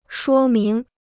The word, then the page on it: 说明: shuō míng